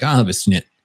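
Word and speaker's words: Schnitt: If you're in Germany, you would ask for, I believe it's pronounced a snit, and it's like, it's basically a pour of beer, but it's half foam.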